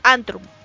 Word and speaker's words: Antrvm: Os voy a decir cómo se escribe. A-N-T-R-V-M. Antrum. ¿Vale? O sea, así como si fuese en latín o Cosas así.